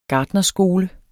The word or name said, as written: gartnerskole